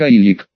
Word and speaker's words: Олег: Не совсем русское имя Олег, а нечто, что произносится примерно как Айлик.